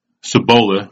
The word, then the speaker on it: Cibola: Cibola is what is how it's supposed to be pronounced. They call it Cibola.